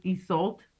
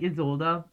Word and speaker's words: Isolde: Her name could also be pronounced as Isolt, but I like Isolde. I think I've always enjoyed that version.